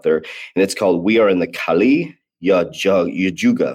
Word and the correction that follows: Kali Yuga: if I say that right, Kali Yuga.